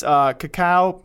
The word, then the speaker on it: Kakao: Frontier Developments, Kakao, probably mispronounced that.